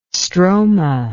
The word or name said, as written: stroma